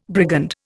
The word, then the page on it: brigand: Indian